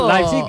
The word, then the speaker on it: Leipzig: Leipzig es lo que nos dijeron que teníamos que decir.